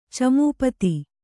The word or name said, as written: ಚಮೂಪತಿ